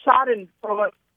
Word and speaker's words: Schadenfreude: Ah, the word is pronounced Schadenfreude. Say it again? – Schadenfreude. Tell them to write this down. It's S-C-H-A-D-E-N. F-R-E-U-D-E, Schadenfreude. – Okay, so we wrote the word, but it's Schaden. – Yeah, Schaden. Mm-hmm. Yeah, it retains something of the German pronunciation.